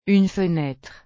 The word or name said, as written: une fenêtre